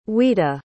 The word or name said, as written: ouida